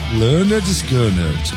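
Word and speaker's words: Lynyrd Skynyrd: Tem que enrolar a língua pra falar mesmo, hein, cara. Tanto que o primeiro álbum dele tem o nome da pronúncia do lado. Lesse Nerded Leonard Skinner.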